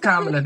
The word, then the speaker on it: Kamala: It's Kamala, bitch. You saying it wrong on purpose.